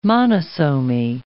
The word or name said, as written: monosomy